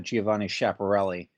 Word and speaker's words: Giovanni Schiaparelli: Yeah, Sawyer, I believe the correct pronunciation is Giovanni Schiaparelli.